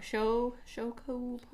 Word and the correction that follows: Shoyu: pork? – Shoyu pork? – Shoyu pork.